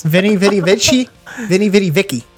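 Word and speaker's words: veni, vidi, vici: C's are a hard consonant. Mm-hmm. Consonant, whatever. So when you say et cetera, you're fucking wrong. It's et cetera. – I didn't even notice. – Vinny, Vidi, Vici. Okay? – Okay. – It's a hard C. Always a hard C.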